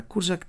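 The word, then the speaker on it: Kurzak: εδώ με την Αλεξάνδρα Κούρζακ, την πολύ σημαντική πλέον, δεν είναι το ρο δεν προφέρεται, Κούρζακ είναι κανονικά,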